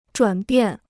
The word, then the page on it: 转变: zhuǎn biàn